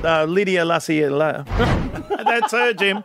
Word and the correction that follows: Lassila: How do you say it? – Lydia Lassila. And then... – Lydia Lassila.